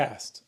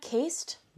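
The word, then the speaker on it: caste: Cast, a cast system. Oh, it's not cased.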